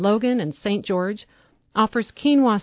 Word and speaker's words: quinoa: In the United States, the word spelled Q-U-I-N-O-A is pronounced quinoa.